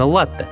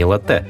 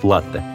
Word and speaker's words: латте: Да, заказывать в кафе нужно именно латте, а не латте. Здесь нужно смотреть на первоисточник. Итальянцы говорят латте.